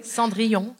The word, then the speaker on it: Cendrillon: Cendrillon.